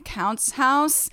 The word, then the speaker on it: Counts House: We love our podcast producer, Ryan Counts House. – Yes. – Rhymes with Bounce House.